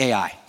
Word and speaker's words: Ai: Side note, it took us way too long to figure out how to properly say Ai. It's two letters. I can't describe to you the amount of time we spent sitting around the table with a bunch of pastors and theologians figuring out how to say that right. It's actually Ai, okay?